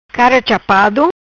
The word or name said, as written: Cara chapado